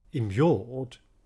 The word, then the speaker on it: immured: Immured, here pronounced as three syllables, immured,